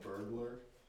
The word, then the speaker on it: Bergler: My last name actually is, it looks like burglar. It's spelled B-E-R-G-L-E-R. But it's pronounced Burglar, technically. I just stopped correcting people, though.